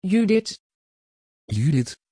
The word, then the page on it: judit: Dutch